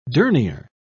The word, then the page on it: dernier: British English